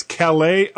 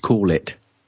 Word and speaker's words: Calais: okay, this is the, the, I think it's Calais iPhone case review. – No, it says it's pronounced "call it." – Oh, oh, you're right. Ah, okay. This is the the call it. I actually that makes more sense because it rhymes with wallet.